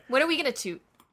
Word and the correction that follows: tout: Tout. It's pronounced tout.